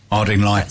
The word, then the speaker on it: Ardingly: Now, that is not me dropping an H. It's spelt with an A. A-R-D-I-N-G-L-Y. Harding Lye.